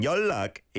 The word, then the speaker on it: 연락: Yeah, and 연락 is one of those that really has the yonum, the sound blending. It's spelt 연락, but said 연락, 연락 for contact.